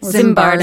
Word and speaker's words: Zimbardo: So our topic today is the psychologist Phil Lizardo. Am I saying that right? – Zimbardo. – Zimbardo. Yeah. I had the Z in the wrong place.